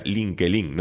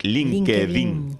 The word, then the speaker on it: LinkedIn: Y lo he dicho bien porque yo que decía LinkedIn, no, es LinkedIn.